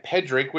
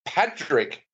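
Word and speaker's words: Pádraic: Is it Pedrick? – Yeah, well, I would say Pe- Pedrick, which is the way that they pronounced it through most of the film. But I know a couple of other Irish people that have actually looked at that and said that it's said as Patrick, as in Patrick with a D.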